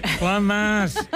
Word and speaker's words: fromage: Fromage. – Fromage.